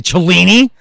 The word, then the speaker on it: Cellini: Nobody fucking talks back to Cellini. It rhymes with meanie. – I'm Cellini the meanie.